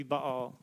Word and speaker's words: Baal: If you want to say it Baal, some people say it that way. The Hebrew pronunciation would be Baal.